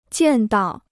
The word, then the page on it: 见到: jiàn dào